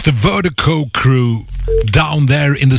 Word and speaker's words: VIRDIKO: And Vertigo, Vertigo is spelled V-I-R-D-I-K-O. That's V-I-R-D-I-K-O, pronounced Vertigo.